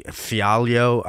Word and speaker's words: Fialho: but he like he beat Andre Fialho. I don't, you know, Andre. How do you say that? Fialho. Yeah, you got it.